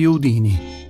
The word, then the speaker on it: Houdini: Uno di questi uomini è stato Harry Houdini, e non con l'accento sulla I finale, come erroneamente si pronuncia.